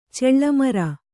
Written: ಚೆಳ್ಳಮರ

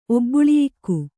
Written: ಒಬ್ಬುಳಿಯಾಗು

